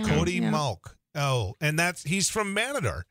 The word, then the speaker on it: Mauch: How do you pronounce M-A-U-C-H? – Mauch. – It's Mauch, right? – Mauch. – Yeah, Mauch. Back in Richland County, it's Mauch.